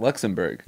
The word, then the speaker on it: Luxembourg: You're saying Luxembourg wrong, too. – Borg? – It's Berg. Luxembourg. – Yeah. – I'm pronounce it B-O-U-R-G.